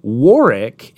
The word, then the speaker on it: Warwick: So Warwick is always pronounced with a silent second W, even Warwick Davis.